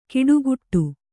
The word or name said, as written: ಕಿಡುಕುಟ್ಟು